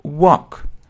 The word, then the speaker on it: walk: Далее, walk. Walk означает прогуливаться. I often walk. Я часто гуляю там. I often walk. Walk гулять. I often walk there. Обратите внимание на произношение. Это чисто O.